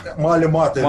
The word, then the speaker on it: Малемата: Так смотри, Махлемот... – Малематы. – Малематы. – Вот, смотри, да, это полное транслитерирование с иврита на латиницу, читается Малемата, но ближайшие мои друзья транслитерируют это как «молись матом».